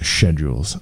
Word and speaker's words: Schedules: I think we thought it was a joke that they were called this, that they called themselves Schedules or Schedules. – Yes, I was about to correct you. – Yeah, Schedules. I'm sorry. I guess that's how it's spelled. So that's how we should say it.